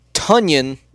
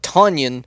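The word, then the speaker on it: Tonyan: I am going to go with Green Bay, although Robert, apparently it's pronounced Tunyon, not Tanyan, as Green Bay released a press release today that said it's pronounced Tunyon like Funyon instead of Onion.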